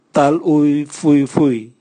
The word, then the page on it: tal uvwi uvwi: Raga